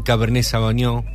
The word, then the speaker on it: Cabernet Sauvignon: Hay más mensajes y Jorge nos manda una fotito allí de un lindo vinito, un Cabernet Sauvignon.